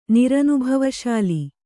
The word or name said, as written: ನಿರನುಭವಶಾಲಿ